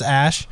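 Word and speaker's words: Æ: It's the symbol, the A, like E thing. Uh-huh. Yeah, but it's pronounced Ash.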